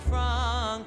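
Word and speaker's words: Franck: Pronounce it Frank.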